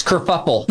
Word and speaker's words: kerfuffle: Prince Harry abdicates England, causes this whole race kerfluffle. – Oh, no, it's kerfuffle. – We knew a kerfluffle happened. – Kerfuffle. – Because this dress kerfluffle. – Pronounced kerfuffle. Kerfuffle.